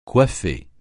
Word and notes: coiffer: francais

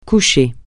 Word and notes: coucher: francais

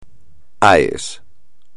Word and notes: AES: espagnol

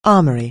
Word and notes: armour-plated: anglais

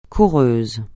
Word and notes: coureuse: French

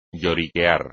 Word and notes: lloriquear: Spanish

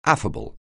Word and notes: affable: anglais

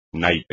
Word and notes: naipe: espagnol

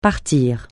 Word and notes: partir: francais

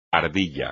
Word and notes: ardilla: espagnol

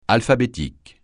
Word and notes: alphabétique: francais

